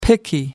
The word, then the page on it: picky: American English